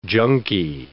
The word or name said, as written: junky